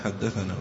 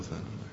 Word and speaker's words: ثنا: القعنبي عن مالك رواه البخاري في صحيحه فقال: حدثنا عبد الله بن مسلمة القعنبي ثنى مالك هي هذه كلمة ثنى هي اختصار لحدثنا، هي تكتب اختصارا وتنطق تماما، تنطق حدثنا ولا تنطق ثنى.